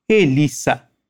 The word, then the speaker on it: Elisa: O nome Elisa em português se pronuncia com o som de Z, mas em espanhol não. Repete comigo: Elisa. – Elisa.